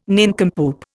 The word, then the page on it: nincompoop: Indian